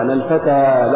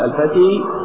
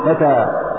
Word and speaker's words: الفتى: الفتى لا تقول الفتي إنما تقول فتى، فتقول هذا اسم منتهي بالألف، فالألف لا تقبل الحركات لأنها يتعذر تماما نطق الحركات عليها، فالمانع هو التعذر.